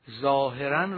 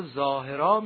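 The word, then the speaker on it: ظاهراً: اما اگر به یاد گرامی شما مانده باشد، عرض کردیم که کلمات متون رو قدما و بزرگان ما با تنوین تلفظ نمی کردند، ظاهرن رو ظاهرا می خواندند، قالبن رو قالبا می خواندند.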